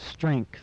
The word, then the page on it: strength: English